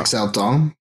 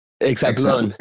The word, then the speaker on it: Exatlón: Now, I haven't seen the show that you did before the challenge, and I'm probably going to butcher this, but is it Exalto, or am I saying that completely wrong? – Exaltón. – Exatlón. Oh, there you go.